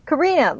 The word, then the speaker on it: Karina: Am I saying it right? Karina? – Karina, like the song.